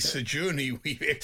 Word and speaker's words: Sigourney Weaver: he struggled with the pronunciation of Sigourney Weaver and called her Sir Johnny Weaver. – It was meant to be Sigourney Weaver.